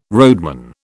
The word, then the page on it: roadman: British English